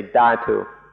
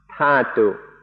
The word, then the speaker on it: dhatu: In English, it's usually spelled dhatu, and so I sometimes say dhatu. In Thai, it's pronounced tatu, so that's why I mix these up.